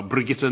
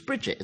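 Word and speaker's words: Brigitte: Hitching a lift home from the pub, stonemason Neville Thompson was picked up by Hollywood star Brigitta Nielsen. – She's Bridget, isn't she? – No, she's Danish. That's no, how you say it in Danish.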